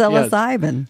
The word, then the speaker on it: psilocybin: One of my friends, a couple of months ago, about a year ago, decided he was going to do psilocybin.